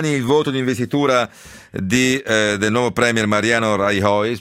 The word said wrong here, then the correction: Rajoy: spero che si pronunci così, no, non si pronuncia così, mi dicono dalla regia, adesso Rajoy, scusate, e però il piano che ha annunciato vale 16 miliardi e mezzo di tagli dove e soprattutto come?